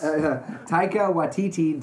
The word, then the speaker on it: Taika Waititi: Taika Waititi did What We Do in the Shadows as well as Hunt for the Wilder People.